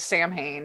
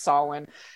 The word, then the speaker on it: Samhain: So after The Misfits, he was in a band called Samhain, which they actually, the fans tend to pronounce it as Samhain because that's how it's spelled, obviously, but it's Samhain.